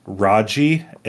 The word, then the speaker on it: Raji: It's Raji, an Ancient Epic.